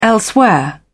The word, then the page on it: elsewhere: British English